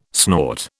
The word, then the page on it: snort: British English